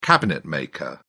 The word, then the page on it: cabinetmaker: British English